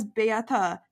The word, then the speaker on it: Beata: If you've ever listened to my episodes with La Brigade, I introduce myself as Beata. That is how my name is pronounced in French.